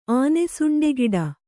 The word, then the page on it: ಆನೆಸುಂಡೆಗಿಡ: kannada